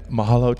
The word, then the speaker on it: mahalo: Mahalo. – And mahalo to you, sir.